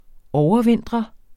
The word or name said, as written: overvintre